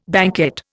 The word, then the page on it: banquette: Indian